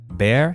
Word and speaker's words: bear: Bear, hair. If I can pronounce hair correctly, I will be able to pronounce bear as well, as long as I hear the diphthong.